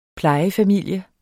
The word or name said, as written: plejefamilie